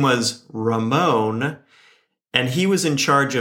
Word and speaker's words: Ramon: To capture Ramon's personality properly, you must roll the R mm. when hmm. pronouncing it if you want to go all the way. Throw your hands up at the end like a bullfighter or a salsa dancer would do.